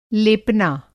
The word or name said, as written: लेपना